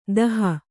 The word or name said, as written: ದೋಹಿ